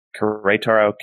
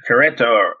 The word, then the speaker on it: Querétaro: One other transfer out of the league is Luis Gil, who is left RSL and has signed with Querétaro. I actually have no idea how to pronounce Querétaro. – Querétaro. It's the second E has the diacritic.